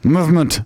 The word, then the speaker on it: MVMT: That's how they spell it. Movement.